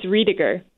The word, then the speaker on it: Riediger: It's Riediger.